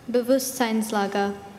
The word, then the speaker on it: Bewusstseinslage: Bewusstseinslage. B-E-W-U-S...